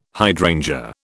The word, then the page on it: hydrangea: British English